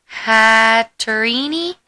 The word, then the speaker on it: Hatterene: And a Holo Hatterene, I believe it's pronounced. – Yeah, there you go.